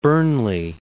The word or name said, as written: Burnley